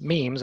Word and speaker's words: meme: And the similar concept is memes. And in fact, the word meme from Richard Dawkins in his Selfish Gene book from 1976 was derived to rhyme with gene.